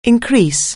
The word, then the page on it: increase: anglais